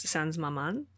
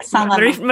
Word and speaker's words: Sans Maman: So when you were creating Unmothered, also known as Sans Maman, did I say that right? Mm-hmm. It's French. – Yeah, Sans Maman.